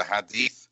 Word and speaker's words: Hadith: And then there's, and I hope I'm pronouncing that correctly, the Hadith, which is a collection of sayings attributed to the Prophet Muhammad. Am I right about that? – Correct.